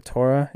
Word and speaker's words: Torah: I mean, my junior senior year, I mean, it was crazy to see, because my freshman year, we're talking about like the Torah. I think I'm saying that right, guy? Torah. The Torah, yeah.